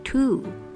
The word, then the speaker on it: two: 数字二的 two 反而是拼字中有 w 却不发音的。O 念的是五五，整个字 two two。